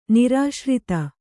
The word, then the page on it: ನಿರಾಶ್ರಿತ: nirāśrita